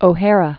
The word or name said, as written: O'Hara